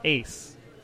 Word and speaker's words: AYCE: I was eating at a sushi place, and it's called Ace, but it's spelled A-Y-C-E.